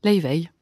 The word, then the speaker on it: Laufey: Elle est islandaise. Leivey. – Ça se dit comme ça ? – Ça se dit Leivey. – Leivey. – Ça s'écrit L-A-U-F-E-Y. Leivey.